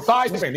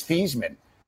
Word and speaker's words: Theismann: Oh, Joe Theismann, the egomaniac.